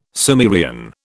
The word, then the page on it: Sumerian: British English